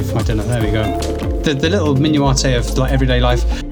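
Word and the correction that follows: minutiae: Minuate? I thought it was minutia. Like, it's spelt really weirdly, right? But it's minutia, I'm sure.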